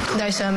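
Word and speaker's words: Disa: Daisa, may I please have any alternate pronunciations? – Just Daisa.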